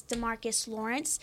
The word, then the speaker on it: DeMarcus Lawrence: Or a main guy I was thinking is DeMarcus Lawrence.